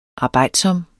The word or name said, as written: arbejdsom